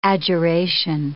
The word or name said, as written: adjuration